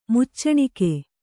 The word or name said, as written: ಮುಚ್ಚಣಿಕೆ